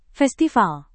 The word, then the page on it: festival: indonesian